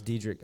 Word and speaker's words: Diedrich: And up until today, I was saying his name wrong. I always thought it was Diedrich Bader. – Oh, Diedrich, yeah, it's Diedrich. I'm pretty sure it's Diedrich.